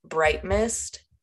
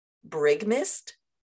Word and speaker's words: Bright Mist: I was pronouncing Bright Mist in my brain wrong for like most of the first book, and then eventually it kind of gets described, but I was reading it as like one word, not bright and mist, you know what I mean?